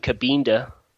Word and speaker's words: Kabinda: Jason Kabinda. Kabinda. – Okay. – It's like Topanga.